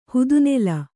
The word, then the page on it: ಹುದುನೆಲ: hudu nela